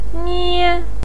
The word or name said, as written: nie1